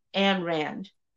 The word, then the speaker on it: Ayn Rand: Her name is, I always say her name wrong. Her name is Anne Rand. Ayn Rand. It always rolls up because I always miss up a letter or something. But she is Ayn Rand.